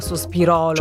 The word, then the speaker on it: Sospirolo: Sospirolo. – Perché qualcuno dice è sospiro, non sospirò, quindi non con l'accento sulla O, sospirolo.